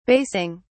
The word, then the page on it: basing: British English